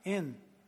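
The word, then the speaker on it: in (ἐν): We'd spell it in English, E-N. And you actually say, like you do, I am, in.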